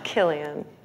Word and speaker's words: Cillian: And he's much more serious than Cillian. It's Cillian. – And I should know that, because I really like Cillian's red beer, so I'm very embarrassed. – Oh, right, of course. – Well. Except that it's spelled K.